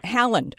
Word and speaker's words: Haaland: Haaland.